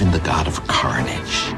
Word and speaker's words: Carnage: Si dice Carnage e noi invece abbiamo detto Carnage. Abbiamo sbagliato l'accento, l'abbiamo messo sulla I dopo perché facevano tutti così a Venezia.